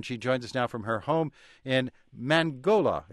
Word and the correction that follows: Mangilao: Did I pronounce that correctly or anywhere close to correctly? – Mangilao.